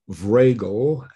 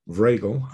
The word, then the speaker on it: Vragel: So you can go to my LinkedIn page, and my name is there in the corner, but it's Paul Vragel, and that's spelled V like Victor, R-A-G like George, E-L. So you can go to Paul Vragel on LinkedIn, or you can go to our website.